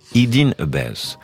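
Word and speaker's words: Eden Ahbez: Eden Abbess, ou comme on prononce en anglais, Eden Abbess.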